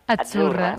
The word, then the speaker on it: Azzurra: La Z no es la Z como decimos en catalán tan sonora, no. Ah, es Azzurra. – Muy bonito, muy bonito.